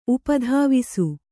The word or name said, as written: ಉಪಧಾವಿಸು